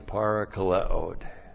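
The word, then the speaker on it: parakaleo (παρακαλέω): Because of this, 2 Corinthians 7, 11 and 12, on account of this, for this reason, I am parakaleoed. Paul is parakaleoed. P-A-R-A-K-A-L-E, long O, parakaleo.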